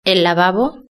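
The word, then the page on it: el lavabo: Spain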